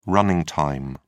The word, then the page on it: running time: British English